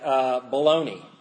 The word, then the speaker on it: bologna: It's like bologna. You wouldn't know how to pronounce bologna because you'd say there's a G and there's an A. It shouldn't be bologna. It should be bologna.